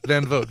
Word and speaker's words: Van Vogt: Van Vogt, V-A-N-V-O-G-T. I think that's how you pronounce V-O-G-T.